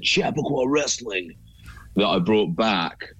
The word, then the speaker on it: Chappaqua: So I suppose the correct pronunciation, because it's a town in America, but the correct pronunciation would be Chappaqua.